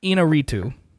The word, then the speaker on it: Iñárritu: I believe his name is pronounced Iñárritu. – Okay. – He's Mexican. – Iñárritu. – There's definitely some rolling R's in there.